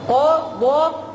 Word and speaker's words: قضى: قضى قضى ألف قصيرة مرسومة على ياء معنى مرسومة على ياء يعني الياء ما بتنطقش يا أولاد إيه اللي بتنطق؟